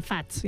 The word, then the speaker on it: FAZ: Sí, Faz, ja ho heu dit.